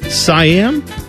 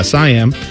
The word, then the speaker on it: Siam: Next up, Siam. I believe it's how you say it. Siam. Like Siamese, right? Siamese cat, that kind of thing.